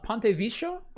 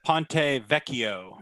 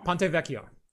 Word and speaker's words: Ponte Vecchio: I believe I'm pronouncing that wrong, but whatever. – Ponte Vecchio. – Ponte Vecchio. Thank you. Ponte Vecchio. I tried to add a little bit too much to it, is what happens.